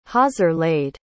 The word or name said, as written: hawser-laid